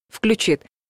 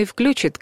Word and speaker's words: включит: При строгом литературном варианте с ударением на последнем слоге «включит» существует разговорный «включит», который в новой редакции Большого орфоэпического словаря русского языка указан как допустимый, хотя раньше был под запретом.